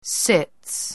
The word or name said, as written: sits